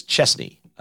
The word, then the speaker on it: Chesney: I say the name wrong. I've said Chesney forever, and he says Chesney. I don't think people know that. You would think that would be two S's. It is Kenny Chesney.